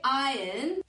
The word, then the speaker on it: iron: Please remember to say iron so that it rhymes with lion. Iron, no iron. Te está diciendo, please remember to say iron. Por favor, recuerda decir iron, que va a rimar con león, lion.